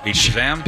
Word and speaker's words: Beat Shazam: Beat Shazam.